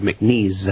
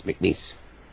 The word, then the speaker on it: McNeese: And you pronounced it correctly, which I would, because sometimes people go McNeese. I said, no, it's like lease, grease, piece, McNeese.